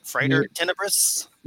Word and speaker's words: Frater Tenebris: Did I say that correctly? Frater Tenebris? – You got it. Exactly.